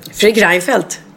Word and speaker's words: Fredrik Reinfeldt: Fredrik Reinfeldt. – Ja, säger jag rätt nu? Ja, nu sa du rätt.